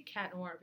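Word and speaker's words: Chat Noir: The way you spell it is C-H-A-T, so like... – Cha. – The T you don't pronounce because it's French.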